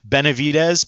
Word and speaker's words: Benevides: Yeah, feel free to leave any comments or follows at Manny, M-A-N-N-Y, Benavides, B-E-N-E-V-I-D-E-S.